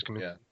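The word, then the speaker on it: Kamiński: Is that how you say his name? – Yes, Kaminsky. Yeah.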